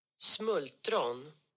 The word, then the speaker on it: smultron: So the correct pronunciation is Smultron. – Go on, Kylie, give it a go.